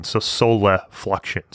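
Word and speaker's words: solifluction: So soliflexion, it's soliflexion. So soliflexion. – Yeah. – Right. So it's kind of a hard word to hear and know how to spell it.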